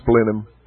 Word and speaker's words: Blenheim: The blue dot with red dot is English, but it's pronounced Blenheim, and there's lots of English words.